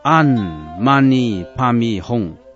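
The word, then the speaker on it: 唵嘛呢叭咪吽: 六字大明咒汉语读作安玛尼帕咪哄，梵语读为嗡玛尼帕咪哄。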